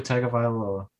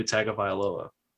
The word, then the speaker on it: Tagovailoa: Isn't it T-U-N-G, like phonetically spelt? – Yeah, it's like Tung. Tagovailoa. Yeah. – Like there's an N in there phonetically. But I think that's how you say it at least. I think that's at least a passable way of saying it, where like some announcers will say it like that.